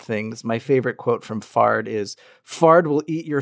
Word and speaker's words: Fard: Fard the Giant, which I'm just going to point out rhymes with Nard.